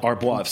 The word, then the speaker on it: Arbois: Arbois.